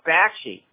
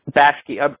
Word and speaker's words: Bakshi: Ralph Boschke, he did the movie version of Fritz the Cat. He did... – Ralph Bakshi. – Bakshi.